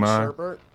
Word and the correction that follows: Shumpert: Iman Shumpert.